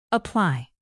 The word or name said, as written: apply